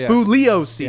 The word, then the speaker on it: Bugliosi: And he's like, Bugliosi. – Yeah, the G is silent.